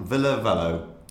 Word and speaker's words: Ville Valo: Ville Valo.